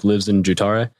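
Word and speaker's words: livsnjutare: And to answer the second part of your question, LIVSN is the first part of a word in Swedish pronounced LIVSN, LIVSNUTARE, which phonetically is more like lives in jutare, and it means one who lives life fully and to the extreme.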